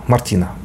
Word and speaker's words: Мартина: Мартина или Март, Мартина, Мартина, правильно, ударение.